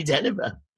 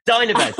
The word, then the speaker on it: Dynevor: So happy 60th to Sally Dynevor. Dynevor, sorry. Happy 60th. I always get a name wrong, Sally.